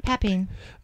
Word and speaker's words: papain: Papain.